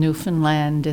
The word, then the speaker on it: Newfoundland: His way of putting it was that in her poems, the accent on Newfoundland is on the third syllable. Of course, that is the way one pronounces Newfoundland.